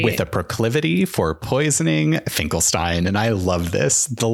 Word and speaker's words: Finkelstein: Stop is is he called Dr. Frank- Finkelstein, not Frankenstein? Finkelstein, yeah. Um, um, they only say his name a couple times, but yeah, she's like this kind of ragged.